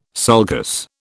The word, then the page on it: sulcus: British English